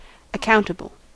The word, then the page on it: accountable: /ʌˈkaʊntəbl̩/; [ʌˈkaʊntəbl̩]; /ə.ˈkaʊn.tə.bəl/